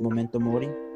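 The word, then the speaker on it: memento mori: So it's pronounced momento mori.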